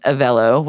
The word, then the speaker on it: Avelo: And I think it's Avelo, which I finally found is how you say it, the A V E L O.